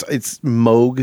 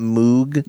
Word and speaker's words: Moog: But you always don't want to be, you know, it's Moog synthesizers, not Moog.